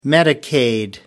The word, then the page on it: medicaid: American English